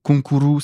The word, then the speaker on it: Konkuru: Et aussi, un autre truc, c'est que konkuru, c'est concours, euh, avec la prononciation japonaise, bon, c'est un peu débile, mais bon, on est un peu débile aussi.